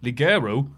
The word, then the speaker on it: Ligero: Ligero, meaning lightweight, is pronounced more like Ligero.